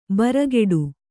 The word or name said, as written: ಭ್ರೂಕೋಟಿ